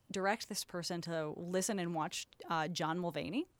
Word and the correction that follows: Mulaney: John Mulaney? – Mulaney, that's the guy.